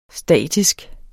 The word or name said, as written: statisk